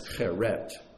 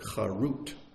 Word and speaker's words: charut: And in the Midrash Rabbah, the rabbis say, don't read it as cheret, pronounce it charut. Same word, just pronounce it differently, because the word charut means freedom.